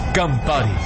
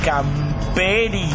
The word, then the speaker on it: Campari: No sabe pronunciar Campari. – Sí, ¿qué le sirvo? – ¿Me das un Camperi? – ¿Un qué? – Un Campana. – ¿Perdón? – Campari.